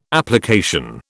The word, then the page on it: application: British English